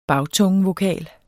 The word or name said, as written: bagtungevokal